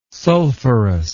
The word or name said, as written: sulfurous